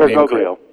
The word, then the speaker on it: Bergoglio: And let's first start by examining who this guy is, Jorge Mario Bergoglio, if I pronounce that Bergoglio.